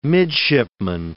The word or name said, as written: midshipman